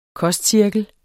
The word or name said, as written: kostcirkel